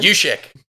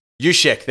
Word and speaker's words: Juszczyk: How do you say his name? – Juszczyk. – Juszczyk. There we go.